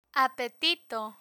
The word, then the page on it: apetito: spanish